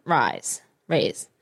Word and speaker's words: Reis: I think that's how you say your name. – Reyes? – Yeah, Alda Reyes. – R-E-I-S.